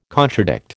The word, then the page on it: contradict: American English